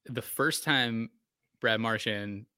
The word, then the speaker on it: Marchand: I recently heard him pronounce his own name as Marchand.